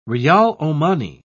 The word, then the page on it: riyal omani: British English